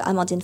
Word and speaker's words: Amandine: Um, it's my first name and last name, so Amandine Flachs.